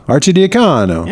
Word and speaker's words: Archidiacono: Ryan Archidiacono. – I can't wait to find out who this really is. – That's, oh my God. How do you say that name? Pronunciation. Archidiacono.